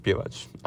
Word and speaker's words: Pivac: Spelled Pivac, but pronounced Pivac. I know, confusing.